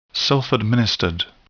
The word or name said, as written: self-administered